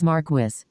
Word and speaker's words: Marquis: While the word is French in origin, it, like so many other things, has been co-opted by the British. Therefore, either pronunciation is technically correct. Also, if you visit the Worm audiobook website, it clearly denotes all author-confirmed pronunciations of character names and lists this particular name as Marquis.